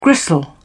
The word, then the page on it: gristle: British English